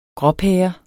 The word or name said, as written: gråpære